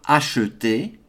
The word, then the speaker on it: acheter: Now, for this sentence, I'm going to pronounce the E on acheter, A-C-H-E. A-C-H-E-T-E-R. I'm going to pronounce that as acheter rather than acheter.